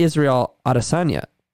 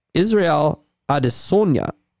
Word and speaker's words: Adesanya: It's not Israel Adesanya. It's Israel Adesanya.